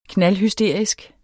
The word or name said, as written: knaldhysterisk